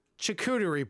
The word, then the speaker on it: charcuterie: Charcuterie board. – Oh my God, you said it right.